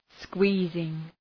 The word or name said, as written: squeezing